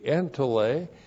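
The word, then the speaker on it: entole: E-N-T-O-L, long E. Entele.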